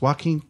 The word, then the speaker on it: Joaquin: It's Joaquin. – It's Phoenix. Joaquin. – It's Joaquin. – That's how he pronounces it.